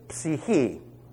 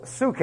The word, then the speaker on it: psyche: The Greek word for soul is psyche. Or as it's pronounced in goofy Bible schools, suke.